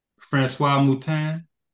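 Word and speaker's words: François Moutin: if I get the name wrong, excuse me, but Francois Moutin. – Yes, perfect.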